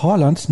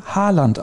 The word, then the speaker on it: Haaland: Das A ist ein O wie in Dorf. Tatsächlich wird auch das D am Ende im Norwegischen nicht ausgesprochen, aber jetzt will ich nicht zu viel erwarten. Das klingt dann auch ein bisschen komisch, wenn man sagt Erling Haaland. Also das D ist so weich, dass es quasi nicht ausgesprochen wird.